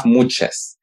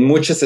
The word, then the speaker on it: muxes: We have muches and muches is spelled M-U-X-E-S.